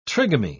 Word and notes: trigamy: British English

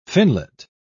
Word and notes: finlet: British English

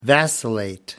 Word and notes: vacillate: American English

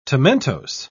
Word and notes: tomentose: British English

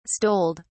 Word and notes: stoled: British English

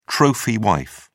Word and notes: trophy wife: British English

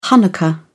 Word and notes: hanukkah: British English

